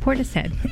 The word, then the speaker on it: Portishead: So not only do I now know he's the drummer, but I also know Portishead is pronounced Portishead.